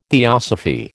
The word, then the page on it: theosophy: American English